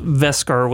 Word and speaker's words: Beskar: But I think that, well, Beskar steel is, which they pronounce Veskar with like a V, but it's a B, so maybe it's just accents, you know, Werner Herzog can't pronounce a B.